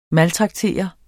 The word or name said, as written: maltraktere